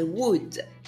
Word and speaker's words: would: Et dans tous les cas, le L ne se prononce pas. Donc vous dites I would.